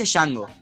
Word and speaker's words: Django: Se dice Django. – Se, dice Django, pero hay una D ahí. Yo no la puedo no pronunciar.